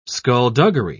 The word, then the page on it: skullduggery: British English